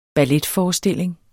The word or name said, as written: balletforestilling